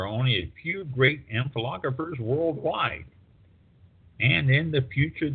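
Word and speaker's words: ampelographers: But there are people, amphilographers, who would. Amphilographers, and that's spelled AMPELOGRAPHY or PHERS, are experts in amphilography.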